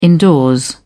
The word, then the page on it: indoors: British English